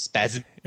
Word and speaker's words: Spasim: It's spelled S-P-A-S-I-M, like Spasm. – Spasm? Anyway. – It's Spasm.